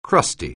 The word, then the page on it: crusty: anglais